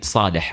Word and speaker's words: Saleh: Because my name, I mean, Saleh, right? – Yeah. – And if you say it, they can't, obviously there's a sa and a ha.